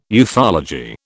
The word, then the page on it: ufology: British English